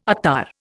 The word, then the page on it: attar: Indian